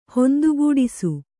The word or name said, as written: ಹೊಂದುಗೂಡಿಸು